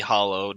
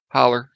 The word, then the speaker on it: hollow: There's a happy hollow near where I used to live. – Well, we don't pronounce it like that. – Oh. – We had an ER to it. No. – Holler? – Holler, yeah.